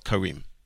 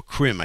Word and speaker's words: Karim: And the appointment was to go to Harav Eyal Karim, or Krim, I think it's pronounced Karim.